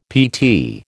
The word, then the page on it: PT: American English